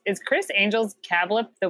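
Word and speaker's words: CABLP: It's spelled cab-L-P, but it's pronounced cablip.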